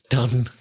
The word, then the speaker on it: done: Now, although done is D-O-N-E, the pronunciation is exactly the same as the vowel sound in cut. It's a.